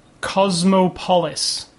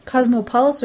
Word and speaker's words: Cosmopolis: Well, like Metropolis is Metropolis, so right. Cosmopolis maybe it would be.